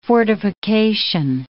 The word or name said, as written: fortification